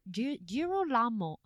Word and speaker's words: Girolamo: And this was all instigated by the Dominican friar, let me see about this pronunciation, Girolamo Savonarola. – Savonarola is how you say his last name. I don't remember how to say his first name. – Girolamo.